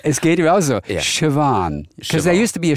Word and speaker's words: Siobhan: S-I-O-B-H-A-N. – Oh, Siobhan. – Oh, it's Siobhan. It's not Siobhan. It's Siobhan.